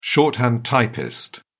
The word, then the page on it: shorthand typist: British English